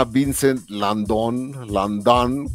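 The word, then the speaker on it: Vincent Lindon: No, este año fue un actor que se llama Vincent Landon, como pronunciándolo en francés, y es siempre que los franceses, que los actores están a cargo, siempre la riegan.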